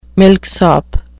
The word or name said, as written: milksop